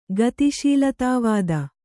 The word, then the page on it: ಗತಿಶೀಲತಾವಾದ: Kannada